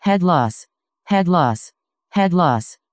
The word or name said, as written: headless